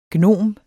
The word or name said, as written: gnom